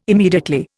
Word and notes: immediately: Indian

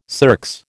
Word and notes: circs: American English

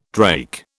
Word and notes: drake: British English

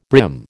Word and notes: brim: American English

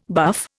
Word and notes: buff: Indian

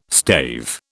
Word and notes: stave: British English

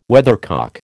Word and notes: weathercock: American English

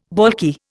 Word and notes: baulky: Indian

